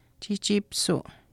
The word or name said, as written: chiihchiipisuu